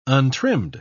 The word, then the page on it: untrimmed: British English